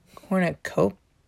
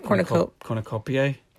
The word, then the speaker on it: cornucopiae: Cornucopia, that's how you say it, but whatever, the plural of cornucopiae. – Cornucopiae, thank you. I don't know why I couldn't say it. – Does it end with A-E? – It's I-A-E.